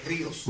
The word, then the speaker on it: Rios: We just so appreciate all of you coming and being here, and especially from Ecclesia Rio. Did I say that right? Ecclesia Rio? – Rios. – Rios. Rivers, not just one river.